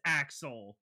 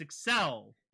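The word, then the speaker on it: Accel: The way they say it, everyone thinks it's Axel, but it's Excel. The word Excel for accelerate.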